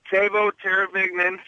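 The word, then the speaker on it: Teuvo Teräväinen: Okay, Tavo Teravignan, Certificate of Authenticity.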